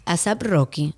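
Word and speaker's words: A$AP Rocky: fruto de su relación con el rapero Azap Rocky, así es que se pronuncia.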